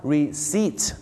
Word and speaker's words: receipt: แย่ฮาร์เลย์เดวิดสันต้องเข้าใจนะครับคุยพี่น้องชาวไทยก็ต้องฮาร์เลย์เดวิดสันแต่ถ้าฝรั่งเนี่ยนะครับก็จะออกเสียงว่าฮาร์ลีย์เดวิดสันนั่นเองครับคำต่อไปครับคำที่ลงท้ายด้วยนะครับที่ด้วยตัว BT หรือ PT เนี่ยนะฮะอยากจะฝากบอกนะครับว่าตัว P กับตัว B นะครับหลายๆครั้งไม่ได้ออกเสียงนะฮะอย่างเช่นคำนี้ครับคำว่า receipt ที่แปลว่าใบเสร็จนะครับบ้านเราชอบออกเสียงว่า receipt นะฮะ receipt ไม่ใช่ receipt นะฮะก็จะออกเสียงว่า receipt